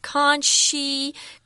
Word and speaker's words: conscientious: If you're conscientious, that's how you pronounce that word, conscientious.